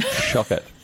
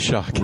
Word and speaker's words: Shockett: Shockett. That is right. It is Shockett. – Rhymes with rocket.